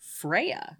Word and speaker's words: Freyja: Okay, so this episode is a deep dive on the Norse goddess Freyja. – Yeah. – Which does, in fact, have a mostly silent J in the way that Americans say it.